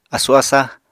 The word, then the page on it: asu-asa: Apatani